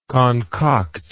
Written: concoctor

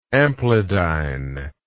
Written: amplidyne

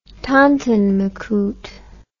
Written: tonton macoute

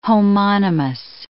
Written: homonymous

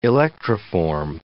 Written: electroform

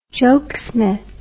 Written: jokesmith

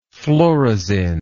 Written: phlorizin

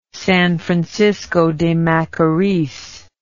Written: San Francisco de Macoris

